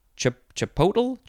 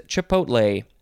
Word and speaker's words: chipotle: We are now at the word chipotle, a word that a lot of people mispronounce. They say chipotle? Chipotle, that's what they say, chipotle. It is chipotle. You could also say chipotle, chipotle, chipotle. Lots of ways, but you can't switch the L and the T.